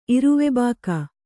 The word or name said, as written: ಇರುವೆಬಾಕ